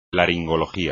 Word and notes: laringología: espagnol

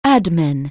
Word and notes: adman: anglais

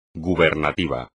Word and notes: gubernativo: espagnol

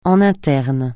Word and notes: en interne: français

